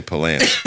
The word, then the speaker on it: Palance: Oh, Jack Palance, right? Isn't that how you say his name? – Well, up in Drums, we say Palance.